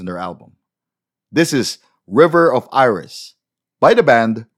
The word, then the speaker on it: RIV3R: Sila po ang bandang River, and that's spelled RIV3R.